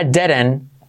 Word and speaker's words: didn't: I wouldn't do it if I didn't. Como derecho, didn't. Esa T no se dice. Didn't need the money. Didn't. La D. La I como E, D, la D segunda como una R.